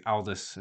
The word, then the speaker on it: Aldous: So in the novel, I think Aldous is how you pronounce his first name. I think Aldous Huxley.